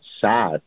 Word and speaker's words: Saad: So for the people who did not understand the play on words, my last name is Saad, S-A-A-D. But interestingly, what you may not know is that Saad, or the proper pronunciation in Arabic is Saad, it has a guttural sound, that's means right.